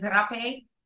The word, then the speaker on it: Rapé: Yeah, it's just pronounced differently. It's originally called Romepoto. And then in Portuguese, they say it as Rapé. But I was getting flagged on certain search engines for the spelling of it, R-A-P-E. And I also didn't like that vibration of what it felt like when you say it. So I was one that started spelling it with an H and always started, pronouncing it as Hapé.